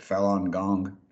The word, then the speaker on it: Falun Gong: Do I say that right? Is it Falun Gong? Is that close? – Yes. Yes. Right. No, that's great. Perfect.